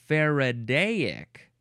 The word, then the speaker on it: Faradaic: And so then that's probably why they changed it to Faradaic. Like, oh, we still get the we have to add that extra syllable, so we still get the right pronunciation of his name, but we also get the IC at the end to make it an adjective.